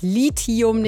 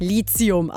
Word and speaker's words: Lithium: Ja, Leute, das wird Lithium, nicht Lithium ausgesprochen, dass wir das schon mal am Anfang hier geklärt hätten.